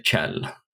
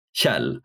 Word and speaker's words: Kjell: Some of the people around there pronounce the KJ as you would in English pronounce a TCH. It could be considered correct to say Chell. I wouldn't say that. I would say Chell, but that sound doesn't exist in English, so it's very hard to explain like how you would actually say it.